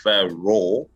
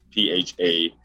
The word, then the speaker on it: PhaRoar: For those who don't know, it's spelled PhaRoar, so like Roar, which is PhaRoar.